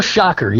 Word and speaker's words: Schacher: Mel Schacher, yeah. Like sticking your finger in an electric socket.